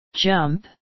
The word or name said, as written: jump